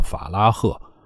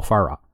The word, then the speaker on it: Farah: 就是取了他的最前面那个叫 Mohammed，就莫后边 Farah F A R A H，大家记住啊，这个 H 是不发音的，所以咱们这儿有些人就是拿着说，哎，我们国家标准这个叫法拉赫。哎，不好意思，我是坚持认为，按照这个发音来要好过什么按照国家标准来，这才是对人家尊敬。莫法拉，莫法拉，但是他坦诚他的真实名字叫什么呀？